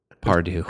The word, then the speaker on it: Pardew: It rhymes with Bargu. Pardew. Mostly rhymes.